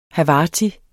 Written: havarti

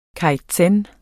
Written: kaizen

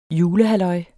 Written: julehalløj

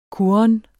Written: kurren

